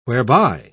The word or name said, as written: whereby